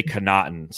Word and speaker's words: Connaughton: Hey, I hear it on local radio. They say Connaughton. So anything that we're saying, what are we? Are we saying it wrong? – I don't know.